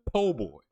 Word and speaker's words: po' boy: You're referring to a po' boy. – Oh, okay. Gotcha. – You don't pronounce the O and the R on that one.